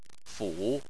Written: fu3

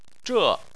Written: zhe4

